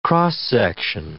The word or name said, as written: x-section